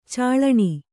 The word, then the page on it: ಚಾಳಣಿ: kannada